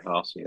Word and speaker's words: Arceus: Okay, so the first question, is how do you pronounce it? – Not Pokemon. – Arceus. Yeah, Arceus.